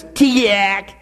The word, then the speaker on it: Teegeeack: And I quote, it was known as Tiak. Look at it. That's exactly how you say it. T-E-E-G-E-E-A-C-K.